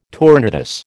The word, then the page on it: tournedos: American English